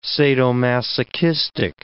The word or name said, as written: sadomasochistic